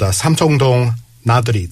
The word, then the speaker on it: 삼청동 나들이: You guys have an original composition called Samjeongdong. Nadori, did I say that right? – Yeah, that's right.